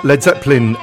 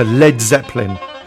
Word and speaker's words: Led Zeppelin: Led Zeppelin. Jimmy loved the name, but dropped the A, so it wouldn't be mispronounced.